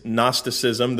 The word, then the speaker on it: Gnosticism: The G is silent. It's Gnosticism.